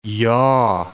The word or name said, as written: yaw